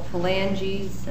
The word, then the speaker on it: phalanges: What is numbered 18 in figure 1, which is referred to as the proximal phalanges, I don't know if I'm saying that right? – Yes, okay. – That's correct.